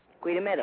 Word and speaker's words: Goeie: Your G is spelled G-O-E-I-E, but your G is pronounced Q.